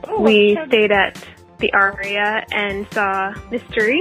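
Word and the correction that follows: Mystère: Is that how you say it? – Mystere.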